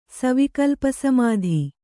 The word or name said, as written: ಸವಿಕಲ್ಪಸಮಾಧಿ